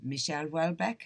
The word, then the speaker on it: Michel Houellebecq: is Michel Houellebecq. Have I pronounced it properly? – You have, yes.